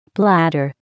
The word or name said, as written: bladder